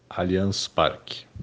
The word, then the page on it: Allianz Parque: ptbr